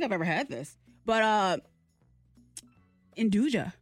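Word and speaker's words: nduja: The correct pronunciation is Andouille.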